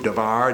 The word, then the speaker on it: Davar: You could spell it in English, D-A-B-A-R. You could spell it, I think, D-A-V-A-R, because the B and the V kind of sound alike in Hebrew, Davar, Davar.